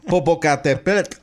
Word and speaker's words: Popocatépetl: De acuerdo con el mapa, la ceniza proveniente del volcán Popocatépetl, ¿cómo se pronuncia? Popocatépetl, podría dispersarse hacia las zonas de Atlixco y la Angelópolis de Puebla, Tlaxcala, Hidalgo, Morelos, Estado de México y Ciudad de México.